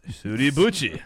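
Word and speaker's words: Suribachi: It's pronounced Suribuchi. – Suribuchi. – But we're American, so it's Suribuchi.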